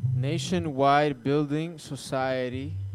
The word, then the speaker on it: Nationwide Building Society: Nationwide Building Society.